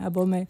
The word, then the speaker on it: Abomey: Abomey. – Perché tutto è francesizzato. – Sì. – Ecco, Benin, Abomey.